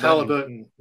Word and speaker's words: Halliburton: Tyrese, Halliburton. Halliburton.